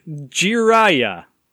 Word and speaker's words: Jiraiya: Jiraiya.